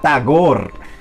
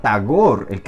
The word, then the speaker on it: Tagore: de India es un poeta que se llama Tagore, Aquí en Colombia dicen Tagore, pero bueno, la R no hay que pronunciarla, Tagore.